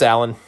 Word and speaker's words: Alain: I was calling him Alain because his name is spelled A-L-A-I-N. And on a previous episode, I was questioning whether that's really how his name was pronounced. But nope, it's Alan.